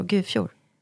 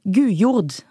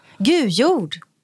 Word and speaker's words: Gujord: Uttalas Hemmings namn så? Gufjord? Gujord. Gujord. – Ja, Gujord vill jag säga sagt.